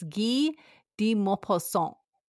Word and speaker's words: Maupassant: So it's Guy de Maupassant.